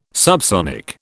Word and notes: subsonic: British English

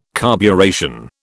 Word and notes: carburation: British English

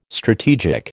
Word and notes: strategic: American English

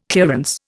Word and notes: clearance: Indian

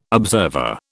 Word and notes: observer: British English

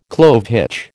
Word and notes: clove hitch: American English

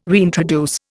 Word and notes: reintroduce: Indian

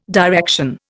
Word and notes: direction: Indian

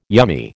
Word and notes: yummy: American English

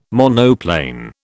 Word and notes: monoplane: British English

